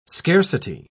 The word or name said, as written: scarcity